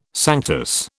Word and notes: sanctus: British English

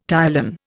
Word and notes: ptyalin: Indian

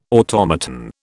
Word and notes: automaton: British English

